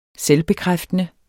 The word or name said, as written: selvbekræftende